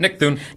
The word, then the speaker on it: Thune: If you want to win passes to see Nick Thune, is that how you pronounce that? – Yep, Nick Thune.